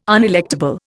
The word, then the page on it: unelectable: Indian